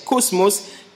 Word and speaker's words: kosmos: Le mot « monde » ici est un mot grec derrière que l'on prononce « cosmos », K-O-S-M-O-S, « cosmos »,